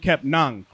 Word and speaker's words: Kepnang: But Frank Kepnang, correct? – Yes, sir.